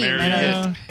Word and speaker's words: Marriott: I always thought it was Marriott until we had to read their name. – Marriott. – Apparently it's Marriott.